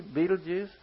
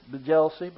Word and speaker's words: Betelgeuse: Y'all remember the movie Betelgeuse? Some people pronounce this Bejelsey, but I like Betelgeuse.